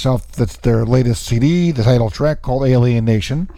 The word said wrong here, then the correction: Alienation: Or Alienation, actually, putting the wrong emphasis on the wrong syllable there.